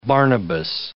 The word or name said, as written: Barnabas